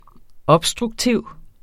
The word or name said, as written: obstruktiv